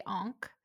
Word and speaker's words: ankh: So I actually don't know how you're supposed to pronounce it because it's Egyptian, but in the US anyway, we say Ankh.